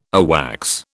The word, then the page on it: AWACS: British English